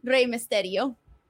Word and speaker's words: Rey Mysterio: Yeah, like Rey Mysterio. Okay. You gotta roll your R's. – The rolling of the R's. So it's like Rey Mysterio.